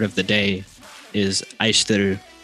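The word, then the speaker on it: Aishiteru (愛してる): Aishiteru. – Aishiteru. Sounds like I said, I shit in a room. – Yes, it's exactly like that.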